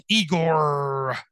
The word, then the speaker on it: Igorrr: Yeah, it's spelled Igor, like yes, master Igor, but with three R's at the end. So I G O R R R.